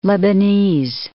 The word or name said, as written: Lebanese